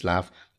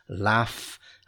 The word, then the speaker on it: laugh: The film which made us laugh. And here we spell laugh, L-A-U-G-H. So be very careful with the pronunciation. Laugh. It's got that F sound at the end.